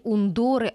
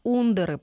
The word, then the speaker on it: Ундоры: Не ундоры, а ундоры, правильно, с ударением на «у».